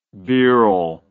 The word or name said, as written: birrell